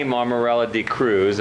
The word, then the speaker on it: Mammarella-D'Cruz: We're with Kenny Mammarella-D'Cruz, if I said that correctly.